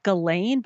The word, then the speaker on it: Ghislaine: Ghislaine Maxwell, whatever her name is. I don't know how to pronounce it. – Her name is Ghislaine, but we say Ghislaine because it's gross and we think it's funny.